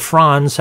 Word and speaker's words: Franz: His name was Frederick Franz, or Franz, how it's pronounced.